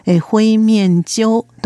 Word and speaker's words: 灰面鳥: サシバは中国語では灰面鳥と言います。灰色の灰、面積の面、鳥は就職するの州の下に鳥という字を書きます。灰面鳥と発音します。